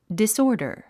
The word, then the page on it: disorder: disɔ́ːrdər; ディスオーダァ